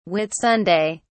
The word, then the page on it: Whitsunday: British English